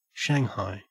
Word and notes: shanghai: Australian English

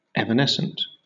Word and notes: evanescent: British English

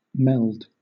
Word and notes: meld: British English

